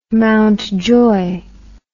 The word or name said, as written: Mountjoy